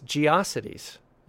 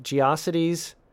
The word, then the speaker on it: GeoCities: I always pronounced it to rhyme with atrocities, so maybe we should say it's GeoCities.